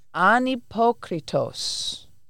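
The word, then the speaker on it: anhypokritos (ἀνυπόκριτος): It's anhypokritos. An is the negative. Mm-hmm. Hypokritos, doesn't that sound like hypocrisy? – Yeah. – And it's spelled with an H, but the H is silent in Greek.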